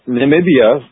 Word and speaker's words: Namibia: Well, tell us a little bit about what is Namibia like, and am I pronouncing it correctly? – Well, Namibia,